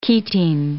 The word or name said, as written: ketene